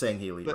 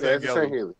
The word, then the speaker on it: Sangheili: The Sangheili, or however you pronounce it, I think it's Sangheili, right? – Sangheili.